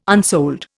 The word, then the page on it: unsold: Indian